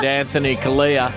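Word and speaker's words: Anthony Callea: Our friend Anthony Kalia.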